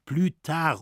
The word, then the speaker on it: plus tard: Das wird ausgesprochen »plus tard« und heißt »später«.